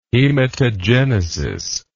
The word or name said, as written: hematogenesis